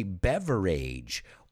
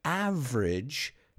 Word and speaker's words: beverage: Words that end in A-G-E are usually not pronounced age. So we don't say beverage or average. It's average. Or beverage. A good way to remember it is this word rhymes with the word bridge. So there's an average beverage under the bridge. Never age. It's only age when it's edad. As a suffix, it's always idge. Idge. Again, it rhymes with the word bridge. Then I said, for some, it's a way of life.